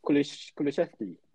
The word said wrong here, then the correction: Kulusevski: Is that how to pronounce it? – Yeah, Kulusevski.